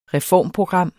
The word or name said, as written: reformprogram